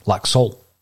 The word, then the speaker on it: Laxalt: and I believe that it's Laxalt with a silent T.